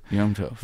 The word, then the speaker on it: Yom Tov: I think it's Naomi Yom Tov, which is good day in Hebrew. – Yom Tov.